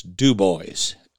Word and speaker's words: Dubois: It's spelled Dubois, and you will get your ass kicked if you call it Dubois. So it's Dubois, Wyoming.